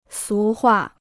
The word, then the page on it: 俗话: sú huà